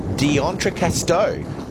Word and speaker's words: D'Entrecasteaux: D'Entrecasteaux, anchored in the bay to shelter from storms and carry out some exploration.